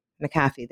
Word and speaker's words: McAfee: Oh, I'm going to say it wrong. Geez, and I interviewed her. Uh, Mac- McAfee. McAfee. There we go.